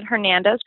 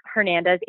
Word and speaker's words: Hernandez: My name is Kristin Hernandez. Kristin is spelled K-R-Y-S-T-I-N. Hernandez, H-E-R-N-A-N-D-E-Z.